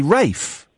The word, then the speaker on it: Ralph: We all know it's spelt Ralph, but why do we say Rafe? – I don't know.